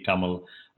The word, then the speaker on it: Tamil: So it's you're right, Tom, and you did very good in pronouncing that. It's Tennessee Tamil Academy.